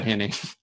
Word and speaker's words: Henne: You got to give Chad Henne a look at some point, right? – You got to play Henne. You got to play Henne against Tennessee. – Yep. Because it almost rhymes with the Tennessee.